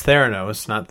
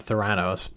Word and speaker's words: Theranos: With this particular one, I'm actually really excited because I know what the story is, and it's Theranos, not Theranos, but you know, who's counting? – I had a little bit of a Marvel tinge to it. – You put the wrong syllable on the wrong emphasis, but or backwards even.